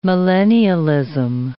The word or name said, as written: millennialism